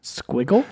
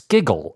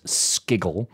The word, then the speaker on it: SQIGGLE: Squiggle? No, I'm sorry. It's Skiggle. It is spelled S Q I G G L E in the Intercal paper, and I believe pronounced Skiggle.